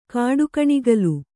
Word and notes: ಕಾಡುಗಣಿಗಲು: kāḍugaṇigalu

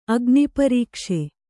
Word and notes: ಅಗ್ನಿಪರೀಕ್ಷೆ: agniparīkṣe

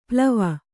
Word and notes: ಪ್ಲವ: plava

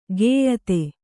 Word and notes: ಗೇಯತೆ: gēyate

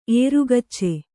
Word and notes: ಏರುಗಚ್ಚೆ: ērugacce

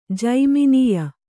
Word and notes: ಜೈಮಿನೀಯ: jaiminīya

